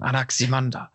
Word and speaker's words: Anaximander: Und so was kann der aussprechen? Anaximander. – Genau, ja. Und auch zu wissen, dass der nicht Anaximenes, müssten wir jetzt KF fragen, ob der Anaximenes oder. Aber die Betonung ist ja wohl immer auf der dritten Silbe.